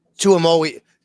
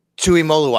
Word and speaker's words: Tuimoloau: JT Tuimoloau. Tuimoloau.